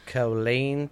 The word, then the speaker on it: Killeen: So, now, my second story about Texas is in Killeen. Is that how you say it? Killeen? – Yes, Killeen, Texas.